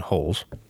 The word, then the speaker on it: Rolls: Hickson and Holes trained together. Now, just so you know, in Portuguese, they pronounce R's as H's. So Rickson and Rolls are Hickson and Holes.